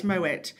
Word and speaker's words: Moët: No, you're quite right, it's Moët. Mm. But it's not for the reasons you think it is. It's because Mr. Moet is Dutch. – Right. – And that's how he pronounces his name.